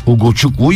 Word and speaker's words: Ugochukwu: Ugochukwu yazıldığı gibi okunur abi yazmışlar.